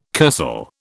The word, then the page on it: kursaal: British English